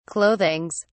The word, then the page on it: clothings: British English